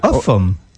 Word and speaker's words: Ulgham: It's the old Danish pronunciation, by the way, Uffam.